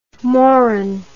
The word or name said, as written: Moran